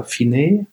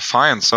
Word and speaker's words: Fiennes: Ralph Fiennes oder Finney, je nachdem, wie man es aussprechen möchte? – Ja, er ist Brite, ne? Ich würde sagen, ist er äh, Fiennes.